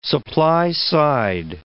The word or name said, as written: supply-side